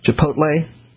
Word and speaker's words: Chipotle: Lynn, I think, was the one who said I would keep mispronouncing Chipotle, which I'm now pronouncing correctly.